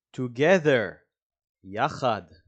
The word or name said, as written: together